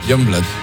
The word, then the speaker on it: Youngblood: Es que era muy difícil de pronunciar, pero luego ¿cómo se pronuncia? – Youngblood.